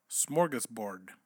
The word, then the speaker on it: smorgasbord: No, you're saying it wrong. – I'm not the one saying it. – Smorgasbord. – Look, listen. – Say it. Listen. Say it. – Smorgasbord. – Smorgasbord. Smorgasbord. Nope. Okay, hold on. Smorgasbord. – Look, we're going to do it slow. – Smorgasbord. – Smorgasbord. – There you go.